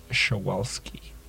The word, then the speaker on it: Przewalski: They're super cute, and also, if you do want to look it up, they are named after this Russian geographer, and so it's spelled P-R-Z-E-W-A-L-S-K-I. It's horse. It's a bit of an interesting spelling, and I believe I am pronouncing it right. It's Schawalski.